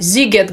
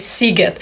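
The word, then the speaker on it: Sziget: Здесь проходит ежегодно один из самых больших фестивалей в Европе, фестиваль Зигет, говорят по-русски, по-венгерски правильно говорить Сигет.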